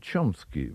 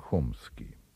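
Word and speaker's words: Chomsky: Американец Ноам Чомски, в России его фамилия произносится как Хомский, один из самых замечательных лингвистов современности и ветеран левого пессимизма.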